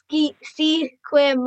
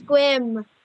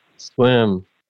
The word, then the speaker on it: Sequim: A lot of people pronounce it Sequim, but it's Squim. – Squim.